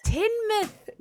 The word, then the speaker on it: Tynemouth: Sorry, it's Tynemouth. – Tynemouth. – Yes, Tynemouth. Yeah, that's how it's pronounced. – I blame my ignorance on being American. – People do get it wrong. Yeah, but it's Tynemouth, yeah.